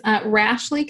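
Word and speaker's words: Rashleigh: Okay, so my website is Rashley Consulting,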